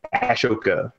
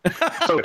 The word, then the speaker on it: Ahsoka: See, there's a misspelling somewhere on one of these things where it says A-S-H, and that's not how it's spelled. So I almost said Ashoka. Ahsoka Tano.